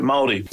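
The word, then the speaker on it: Māori: Maori.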